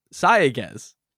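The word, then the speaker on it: Sayagues: In any case, this movie was directed by Rodo Sagaez. – Rodo the Barge Sagaez. – I'm sorry, I said his name wrong. Sagaez. Put the syllable in the wrong spot.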